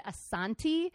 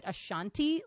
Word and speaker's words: Asante: Now, all of the African pronunciation guides said Asante, okay. But a lot of people from Western Europe and North America pronounce it Ashanti, like okay. The singer, but I'm pretty sure it's Asante.